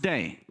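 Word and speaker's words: day (δεῖ): Now, this word in the Greek is pronounced day.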